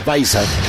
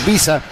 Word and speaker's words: Baiza: Se escribe B chica I Z A. Así se escribe el apellido de Baiza. Algunos podrían decir Tyler Visa.